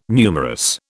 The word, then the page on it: numerous: British English